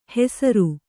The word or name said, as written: ಹೆಸರು